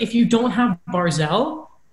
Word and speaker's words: Barzal: I think it's Barzal. – Barzal, sure.